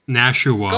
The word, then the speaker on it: Nashua: And 3 syllables. Nashua.